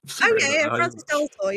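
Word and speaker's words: Frances de la Tour: It's like France's, like the plural of France, de la Tour. – Francis de la Tour. Yeah. You are actually right with Francis, I think.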